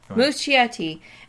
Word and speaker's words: Muschietti: I'm going to butcher the name, but the director's name is Andreas Muschietti, yep. – Muschietti.